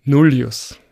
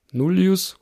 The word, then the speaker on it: Nullius: Weil eine der lateinischen Betonungsregeln sagt, dass in einer Wortform, deren vorletzte Silbe einen langen Vokal hat, diese Silbe betont ist. Und tatsächlich ist bei Nullius oder Nullius oder Nullius das I im Gegensatz zum zweiten I von Filius lang.